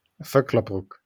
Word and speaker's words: Vöcklabruck: Um, pr- pronunciation-wise, you're born and brought up in Volkerbruck. Vöcklabruck. Vöcklabruck, yeah. Vöcklabruck, yeah. Th- thank you.